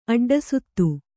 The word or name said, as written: ಅಡ್ಡಸುತ್ತು